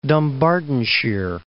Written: Dumbartonshire

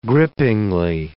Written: grippingly

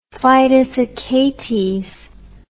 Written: fidus Achates

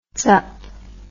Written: tsup

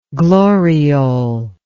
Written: gloriole

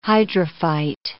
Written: hydrophyte

